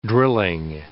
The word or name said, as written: drilling